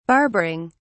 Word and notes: barbering: British English

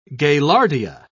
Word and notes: gaillardia: British English

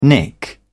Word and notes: nick: British English

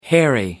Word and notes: hairy: American English

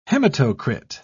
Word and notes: hematocrit: British English

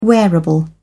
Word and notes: wearable: British English

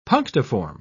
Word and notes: punctiform: British English

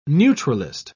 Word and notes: neutralist: British English

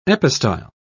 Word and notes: epistyle: British English

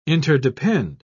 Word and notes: interdepend: British English

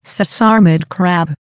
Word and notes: sesarmid crab: American English

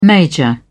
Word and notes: major: British English

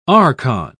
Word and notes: archon: British English

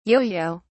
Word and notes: yoyo: British English

